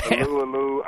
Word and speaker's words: Alualu: Tyson Alualu, or how do you say it? – Tyson Alu Alu. – Yeah, people keep telling me it's Alu Alu, and it's a little less fun for me to say.